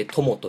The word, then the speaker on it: 強敵: 強敵と書いて友と読